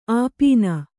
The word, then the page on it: ಆಪೀನ: āpīna